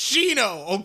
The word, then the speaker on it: Shino: because the I is silent, so it's Shino, okay?